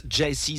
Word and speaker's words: Gyasi: Il s'appelle Jesse, prononce Jesse, c'est le nom de cet album. Jesse, ça s'écrit G-Y-A-S-I si tu veux faire des recherches.